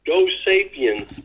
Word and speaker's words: Go Sapiens: Go Sapiens of Go Sapiens, hoe moet ik het uitspreken? – Go Sapiens. – Dus ik zei het wel goed in eerste instantie.